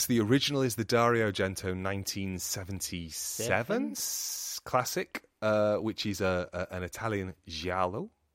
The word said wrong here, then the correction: giallo: which I actually said that wrong. I said that with a Portuguese accent. – Giallo. – So you say giallo.